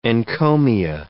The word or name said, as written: encomia